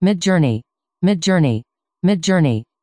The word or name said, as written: midjourney